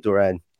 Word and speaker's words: Duran: But yes, I think it is John Duran, and I think the U is pronounced with like a double O. That's what I've been told so far. So John Duran.